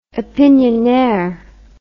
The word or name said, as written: opinionaire